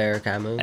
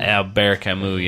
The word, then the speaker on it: Albert Camus: Albert Camus. – Albert Camus, yeah, yeah.